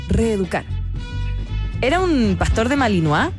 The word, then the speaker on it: Malinois: Pastor de Malinois. Milenois se escribe. – Mira, yo le hubiese dicho milanesa.